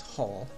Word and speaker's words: Hull: The week after, I went to UK myself, specifically in Hull, which is spelled H-U-L-L, but it's pronounced Hall.